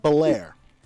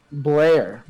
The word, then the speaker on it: Belair: Bianca Belair. – Belair. – Belair, like French Belair. Prince of Belair. Like, Belair.